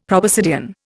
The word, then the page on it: proboscidean: Indian